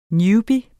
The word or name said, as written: newbie